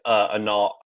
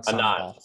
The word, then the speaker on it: Anand: It's Anand. A-N, I don't know how to pronounce it. A-N-A. – Anand. – Anand. Sorry, Anand.